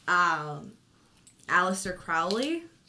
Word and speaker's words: Aleister Crowley: He was talking about, like, Aleister Crowley. Is that how you say his name? – Yep.